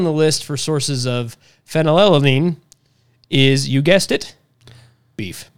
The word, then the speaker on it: Phenylalanine: Phenylalanine. That can't be the right way to say that. – Phenylalanine. – Phenylalanine. – Yep.